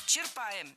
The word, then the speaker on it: черпаем: Черпаем, неверно. Правильно, черпаем. Здесь ударение тоже должно падать на корень слова. Черпать, черпаешь, черпает, черпаем.